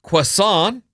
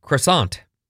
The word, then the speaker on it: croissant: In croissant. French, it's croissant. – Croissant. – In English, it's often pronounced croissant, like I just did.